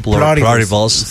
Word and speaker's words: Pluribus: No sé si así se pronuncia, Pluribus o Pluribus. – Pluribus. – ¿Cómo será en inglés? – Pluribus.